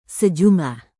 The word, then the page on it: sejumlah: indonesian